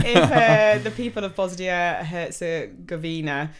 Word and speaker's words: Herzegovina: I say Herzegovina, but it's Herzegovina.